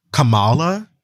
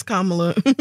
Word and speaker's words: Kamala: I really struggle not to call her Kamala. – Yeah. – Because. – No, it's Kamala.